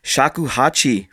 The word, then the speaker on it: shakuhachi: Joining me tonight all the way from Cape Town is actor, musician, and shakuhachi flute craftsman. Am I saying that right? – You're absolutely saying that right.